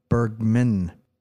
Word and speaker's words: Bergman: Bergman.